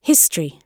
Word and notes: history: British English

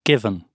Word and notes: given: British English

